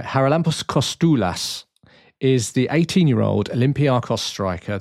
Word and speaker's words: Kostoulas: So the thing I got wrong was apparently it's a silent C at the beginning of our new 18-year-old wonder kid. Right, so... Haralampos Kostoulas.